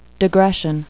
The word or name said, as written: digression